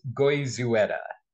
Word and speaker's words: Goizueta: You're close. It's Goizueta. – Goizueta. See, I knew it. – Yeah, it's hard. You got to make sure you pronounce every letter.